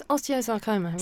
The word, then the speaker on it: osteosarcoma: And I have Clare with me in the studio, and Clare was 17 when she had an osteocarcinoma. Did I say that right? – It's osteosarcoma.